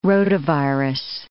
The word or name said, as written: rotavirus